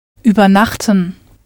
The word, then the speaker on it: Übernachten: Übernachten. Übernachten, which means to overnight. Note there's no stress on the first syllable, so it's not separable.